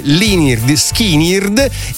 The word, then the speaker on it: Lynyrd Skynyrd: Lynyrd Skynyrd in Lynyrd Skynyrd. Non si sente quasi la A e non si sa che è una U poi, anzi è una Y in realtà, e non si sente praticamente per niente la R.